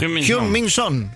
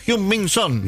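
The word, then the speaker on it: Heung-min Son: Pero te pido, por favor, que lo pronuncies bien como lo pronunció Manu Antuña. – Hyun-min Son. – Muy bien, fantástico. – Otra vez fantástico. – Que te lo diga, Hyun-min Son.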